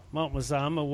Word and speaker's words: Mazama: Yes, I have been to its sister mountain also, which is Mount Mazama. Is that how it's pronounced, John? – Yes, I believe so. Mount Mazama, which...